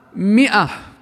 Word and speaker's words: مئة: فهي مكتوبة بألف وكأنها ماء، وفي ناس بيخطوا فيها بيقرأوها هكذا، لكنها لا تنطق إلا مئة ومئتين، فاحذر أن تخطئ بها.